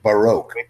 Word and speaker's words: Baroque: Baroque. Please say Baroque. This is the correct pronunciation of this word.